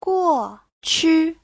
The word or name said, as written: guo4qu5